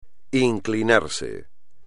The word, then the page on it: inclinarse: espagnol